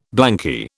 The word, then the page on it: blanky: British English